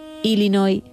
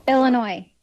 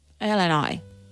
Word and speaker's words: Illinois: Él, español, ella estadounidense, concretamente de Illinois, y fue quien grabó este audio para que escuchemos cómo se dice realmente. – Aquí te doy la vuestra. – Illinois.